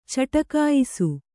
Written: ಚಟಕಾಯಿಸು

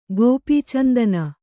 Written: ಗೋಪೀಚಂದನ

